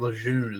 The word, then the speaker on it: Lejeune: Oh, the Wikipedia say he was born in the Marine Corps Base Camp Lujing. – So he was born his mother or father was in the military, I think. It was Camp Lejeune is how you pronounce that in North Carolina.